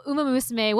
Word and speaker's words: Uma Musume: Uma Musume.